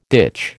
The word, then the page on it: ditch: American English